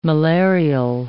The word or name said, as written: malarial